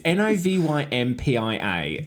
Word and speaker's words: Novympia: So just to clarify, Novympia is a combination of Nova and Olympia. It's Novympia, and it's spelled just exactly how it sounds.